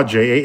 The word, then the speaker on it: Jah: Pronounced as it's spelled as it's pronounced, and Jaw, Jah.